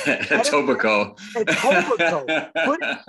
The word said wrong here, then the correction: Etobicoke: Uh, for the people who live in Etobicoke, I am very sorry I butchered that name.